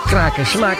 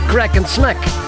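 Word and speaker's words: Kraak & Smaak: Want de Engelsen die zeggen Kraak en Smaak, dat kunnen ze niet, maar die zeggen Crack and Smack.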